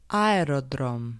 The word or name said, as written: aerodrom